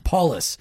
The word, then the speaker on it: Paules: Paules. – So not as much emphasis at the end.